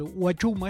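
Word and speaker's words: Huachuma: El Guachuma está escrito en grafía castellana con H al principio, H muda, ¿no?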